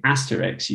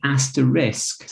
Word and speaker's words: asterisk: Did you know it's actually an asterisk? So the word is actually asterisk. I mean, it actually means little star. But most people now pronounce it asterix.